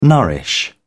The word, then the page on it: nourish: British English